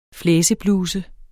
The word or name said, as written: flæsebluse